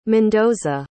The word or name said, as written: mendoza